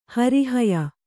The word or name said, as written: ಹೊರಹಾಯು